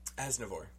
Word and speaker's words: Aznavour: Aznavour? How do you say it? – Aznavour.